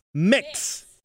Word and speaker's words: Mx: By the way, you're still saying it wrong. It's MX, Mix.